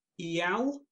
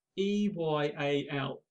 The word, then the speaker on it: Eyal: Yeah, so there's one that I've learned since we were watching episode two, and his name's Eyal. – Repeat that for me. – So it's pr- it's spelt E Y A L.